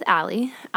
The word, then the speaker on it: Ali: Firstly, a reminder, if you're looking for me on social media, it's Drinks with Allie. Allie is spelled A-L-I.